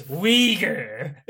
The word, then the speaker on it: Uyghur: There's no I in Uyghur. – It's Uyghur. That's how you say it.